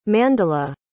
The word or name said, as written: mandla